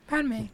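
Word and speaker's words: Padmé: Padme.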